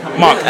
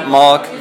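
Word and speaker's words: Marc: Mark.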